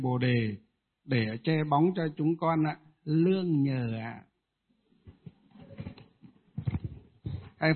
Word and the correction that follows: nương nhờ: Lương nhờ hay là nương nhờ? Nương nhờ chứ không phải là lương nhờ.